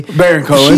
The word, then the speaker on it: Baron Cohen: But yeah, Sacha Baron Cohen, I think I'm saying his name correctly. – Baron Cohen.